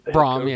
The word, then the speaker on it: Brohm: and is the last name Brohm? Is that's how you pronounce his last name, right? – Brohm, yeah, yep.